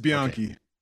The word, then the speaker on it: Bianchi: Is it Bianchi, or how are you supposed to pronounce it? – Yep, you're right. It's Bianchi.